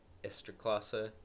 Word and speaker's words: Ekstraklasa: But he's playing in the Ekstraklasa in Poland.